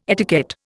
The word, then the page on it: etiquette: Indian